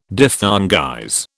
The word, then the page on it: diphthongize: British English